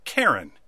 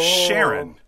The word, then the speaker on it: Charon: The Charon from Greek mythology is actually pronounced Karen and not Sharon.